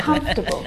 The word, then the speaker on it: comfortable: I've always wondered about comfortable. – That. – Now, this word, just like vegetable, has an extra vowel in there that we don't need to pronounce.